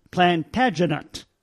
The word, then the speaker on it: Plantagenet: If you listen to historians talking about that dynasty, they pronounce it Plantagenet. Plantagenet. So, if you want to sound like a historian, you go with Plantagenet.